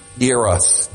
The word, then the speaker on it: Eros: Eros, depends how you wish to pronounce it. Eros is how I pronounce it.